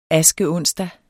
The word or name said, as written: askeonsdag